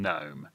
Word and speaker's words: Nome: From the green fields of Virginia to the gold fields out in Nome. Nome is a bit too obviously and obtrusively chosen purely because it's a town that happens to rhyme with home sweet home.